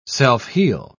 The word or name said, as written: self-heal